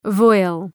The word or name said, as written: voile